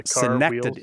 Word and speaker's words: synecdoche: Synecdoche, is that the pronunciation of the word we had earlier? They're like calling synecdoche. – It's like you got to pronounce like New York. – Synecdoche. – Okay. Gotta hit the neck.